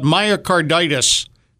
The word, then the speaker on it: myocarditis: Today's show, we're going to talk about myocarditis. Did I say it right? – You did. – Okay. – You got it right.